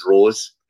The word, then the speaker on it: Roos: Yeah, and it's actually, if it's Roos, and it's the way it'll be pronounced is Rose.